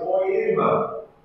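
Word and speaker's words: poema (ποίημα): Y esa palabra hechura, en griego, se pronuncia poema, y quiere decir obra de arte.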